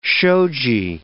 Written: shoji

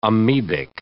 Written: amoebic

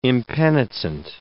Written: impenitent

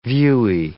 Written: viewy

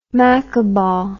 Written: maccabaw

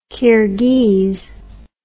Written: Kyrghiz

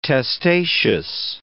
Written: testaceous

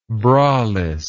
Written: bralessness

